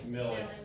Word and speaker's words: Milley: Is his name pronounced Miley or Millie? – Millie.